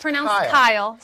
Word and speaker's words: Keil: But it's K-E-I-L. – Yes. – It's pronounced Kyle, spelled Keil. Yes.